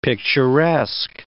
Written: picturesque

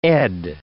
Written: Ed

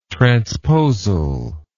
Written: transposal

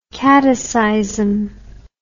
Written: kataseism

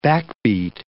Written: backbeat

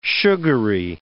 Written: sugary

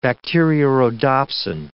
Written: bacteriorhodopsin

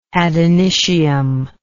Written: ad initium

